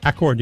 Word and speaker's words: Accord: Heather Ballard is in Accord. – Accord, yeah.